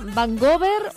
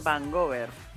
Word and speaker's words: Vancouver: Abonnés se llama Vancouver, ¿o cómo lo dices tú? ¿Cómo lo pronuncias? – Así mismo es, Vancouver.